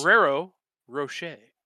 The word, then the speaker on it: Ferrero Rocher: Ferrero Rocher.